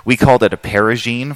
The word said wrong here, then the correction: Parisienne: for a long time until somebody corrected us. And I think he said, no, it's Parisienne.